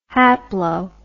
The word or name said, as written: haplo